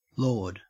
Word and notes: lord: Australian English

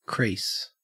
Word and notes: crease: Australian English

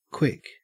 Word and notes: quick: Australian English

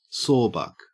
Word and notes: sawbuck: Australian English

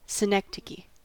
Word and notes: synecdoche: American English

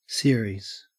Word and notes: series: Australian English

